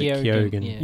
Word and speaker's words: Keoghan: I used to say Keegan, but he pronounces Keoghan.